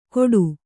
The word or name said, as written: ಕೋಟು